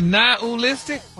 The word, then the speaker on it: nihilistic: But you actually pronounced it correctly the last time you said it. – Really? Nihilistic? – Wow. – Stop putting the ooh in there.